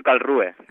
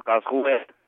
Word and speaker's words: Karlsruhe: Una pregunta, jo la pronuncio Karlsruhe, però cada alemà la pronuncia com vol, Karlsruhe, però bueno, però els castellanohablants i catalanoparlants, Karlsruhe.